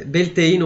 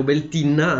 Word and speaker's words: Beltane: qualcuno nei commenti mi dica fermatelo mentre Beltane o Beltane o Beltinna anche come pronuncia viene celebrato a maggio e eh,